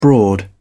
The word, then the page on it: broad: British English